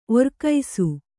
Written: ಒರ್ಕೈಸು

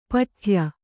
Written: ಪಟ್ಟೆಯ

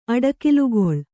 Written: ಅಡಕಿಲ್ಗೊಳ್

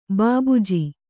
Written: ಭೂಭುಜ

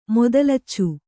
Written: ಮೊದಲಚ್ಚು

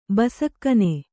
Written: ಬಸಕ್ಕನೆ

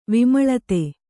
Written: ವಿಮಲತೆ

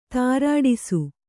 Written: ತ್ರಾಡಿಸು

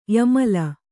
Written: ಯಮಲಿ

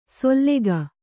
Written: ಸೊಲ್ಲೆಗ

